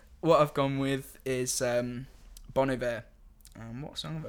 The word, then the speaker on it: Bon Iver: I think it's pronounced Bon Iver in French, so Bon Iver it is in English.